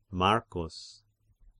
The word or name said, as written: Marcus